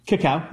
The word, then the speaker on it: cacao: Is that how you pronounce it? Cacao? – Cacao. Yeah.